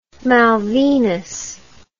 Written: malvinas

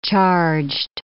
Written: charged

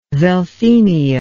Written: veltheimia

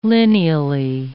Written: lineally

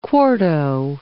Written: quarto

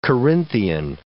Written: Corinthian

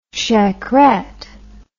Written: shackerette

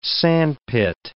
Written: sandpit

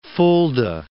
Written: Fulda

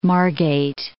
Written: Margate

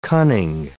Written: cunning

